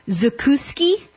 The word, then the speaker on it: zakuski: It has a name, zakuski. – Yes. – It's pronounced right.